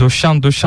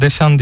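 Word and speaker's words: Deschamps: Yani bunu Türk spikerler özellikle Fransızca'da böyle E'ler Ö okunur mantığıyla döşan yani diye vurguluyorlar. O yanlış bir telaffuz, onu bir söyleyelim. İki yani. Sen Josef'li olarak orada E'den sonra S geldiğinde onu Deşan diye okumak gerekiyor.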